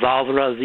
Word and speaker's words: volvere: Volvere, V-O-L-V-E-R-E, to roll.